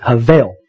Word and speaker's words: hebel: We would spell it H-E-B-E-L, but you would pronounce it havel.